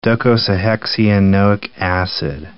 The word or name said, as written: docosahexaenoic acid